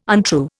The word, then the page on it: untrue: Indian